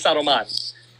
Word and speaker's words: Saruman: Saruman. – He was Saruman.